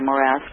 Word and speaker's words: morass: He doesn't have to deal with anything other than emphasis on that second syllable there, morass.